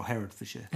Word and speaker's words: Herefordshire: Herefordshire. – Right. – And Somerset. – Is Herefordshire named after King Herod? – No. – Did you say Hertfordshire or Herefordshire? – Herefordshire, yeah.